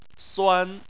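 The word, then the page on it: suan1: tone 1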